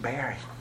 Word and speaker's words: Barry: So that child, hey, my name's Mr. Barry. It rhymes with Harry.